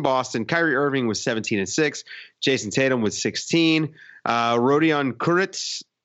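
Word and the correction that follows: Kurucs: is that pronounced correctly, Rob? – Rodion's Kuruks.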